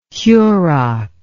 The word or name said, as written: Hurok